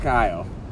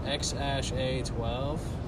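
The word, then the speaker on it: X Æ A-12: I guess he said on the podcast it's pronounced X-A-12.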